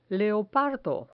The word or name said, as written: leopardo